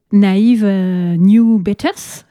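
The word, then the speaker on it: Naive New Beaters: Alors oui, cette deuxième passion entre l'acting et puis votre groupe, Naïve New Betters, c'est ça? – Ouais, c'est ça. Hein, pas mal prononcé. Pas hyper bien non plus, mais bien prononcé quand même. Enfin, bien tenté de la prononcer.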